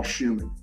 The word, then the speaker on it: Schumann: Same way, Paul Schumann. My last name is spelled S-C-H-U-M-A-N-N, as in the composer Robert Schumann. S-C-H-U-M-A-N-N.